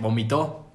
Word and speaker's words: vomitó: Acento en la última. Vomito. Vomito. No, porque eso quiere decir que alguien más vomitó. No. Sí, es vomitó.